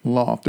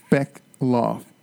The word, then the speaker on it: Bechtloff: Bechtloff. There's a T in there, like Bertolt Brecht. And with so the T is silent.